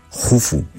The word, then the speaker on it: Jufu: se escribe Keops, pero se pronuncia Jufu, y casi nadie llama Jufu, porque Heródoto nos lo tradujo del griego y bueno, pues ahí se ha quedado, ¿no?